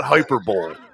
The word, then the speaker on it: hyperbole: And I say this without any sort of hyperbole. Or I'm sorry, I'm talking to Taylor here, who likes to mispronounce things. It's not hyperbole.